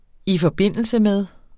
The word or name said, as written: i forb. med